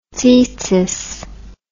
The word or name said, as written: tsitsith